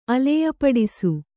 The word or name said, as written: ಅಲೆಯಪಡಿಸು